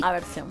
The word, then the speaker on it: aversión: Adversión. ¿Está bien dicho? – No, aversión.